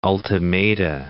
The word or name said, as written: ultimata